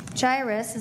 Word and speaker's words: Jairus: Then a man named Jairus, is that how you say it in English? Yep.